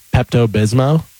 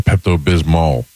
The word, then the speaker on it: Pepto-Bismol: Pepto-Bismo? – I thought it was Pepto-Bismol. – Yeah. – There is an L on the end. – Oh, there is. – Pronounce the L? I always pronounce it Pepto-Bismol.